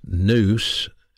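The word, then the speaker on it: nous (νοῦς): It's in the mind, pronounced nous, and the heart, pronounced cardia in the New Testament, cardia, cardiac, cardiology.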